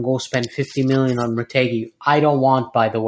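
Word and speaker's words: Retegui: And also, if I butcher this, is it Retegi? – Oh, Retegi, yeah.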